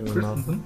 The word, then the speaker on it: Christensen: Ich kann den nicht so aussprechen. – Christensen? – Genau so.